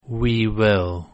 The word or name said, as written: we will